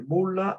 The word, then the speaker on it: Boulle: Il film si discosta molto, è tratto da un romanzo di Pierre Boulle, scritto Boulle, si legge Boulle, è un autore francese